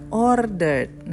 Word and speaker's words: ordered: There were giant elephants he had ordered. Ada yang salah? – Hmm, pas sebelumnya banyak kedengeran T pas kok harusnya D. – Nah, ordered.